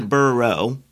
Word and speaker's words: Burrow: The Burrows, if you know that from Harry Potter. – Yeah, although Marcus told me I was saying it wrong and I embarrassed him on Instagram. Apparently, it's the Burrow single.